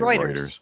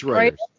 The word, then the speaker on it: Reuters: R-U-E-T-R-S, I think it is. Reuters. Yeah. Well, how do you say that? – Yeah. – I say Reuters. Reuters. – It's Reuters. Reuters.